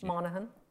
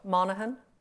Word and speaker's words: Monaghan: Monaghan. – What? – Monaghan.